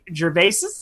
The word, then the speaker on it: Gervais: Ricky Gervais. Did I butcher that one? – Gervais. – Gervais. – You just added an extra S.